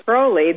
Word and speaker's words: Crowley: If she really is the daughter of Aleister Crowley, then, or Crowley, as Americans like to pronounce it, that would make her demon spawn.